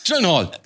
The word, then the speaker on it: Gyllenhaal: Gyllenhaal, Dankeschön. – Ja, das, das ist Aktionen. Ach so. that is pronounced correctly, my last name, like in Sweden and in IKEA.